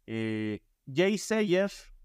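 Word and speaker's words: Yeasayer: Están Jay Sayer, que se escribe Yasayer.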